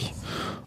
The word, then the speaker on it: Neato: Nitto D85. – Das schreibt man wie Netto? – Nee, wie Neat, also das englische Wort für hübsch, sauber, ordentlich. Neato. Und ein O hinten dran.